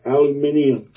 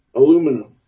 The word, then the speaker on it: aluminium: I would always pronounce it aluminium. – That's how they say it over there. – I know. I found that out much, much later, that they decided in 1925 to make this pronunciation officially aluminum.